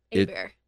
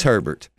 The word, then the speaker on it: Herbert: Is it Herbert or is it Hebert? – It's however you want to pronounce it. – It's Herbert. But it's Hoover. – Unless you're French, then yeah. It's Hebert.